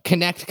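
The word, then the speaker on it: Knecht: Well, if you're in the Chicago area, Connect Chiropractic. And I realize that we haven't spelled this every time. It's K-N-E-C-H-T. Pronounced Connect Chiropractic.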